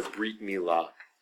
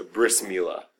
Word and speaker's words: brit milah: Um, brit milah, sometimes known as a bris, because uh, um, the Hebrew word brit, which means covenant, um, in uh, uh, the Ashkenazi pronunciation of it, right from European Jews, is a bris, a bris milah instead of a brit milah. Um, the Sephardi pronunciation of the t, the Tuv in, uh, in, in Ashkenazi circles was usually a soft s.